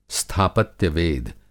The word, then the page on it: स्थापत्यवेद: Hindi